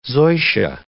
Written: zoysia

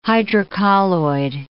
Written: hydrocolloid

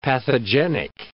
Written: pathogenic

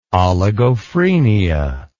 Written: oligophrenia

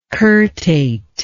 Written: curtate

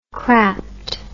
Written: crapped

